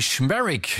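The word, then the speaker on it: Szmierek: Anthony Schmerick, genau so wird er ausgesprochen.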